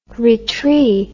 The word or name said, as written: retree